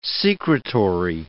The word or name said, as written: secretory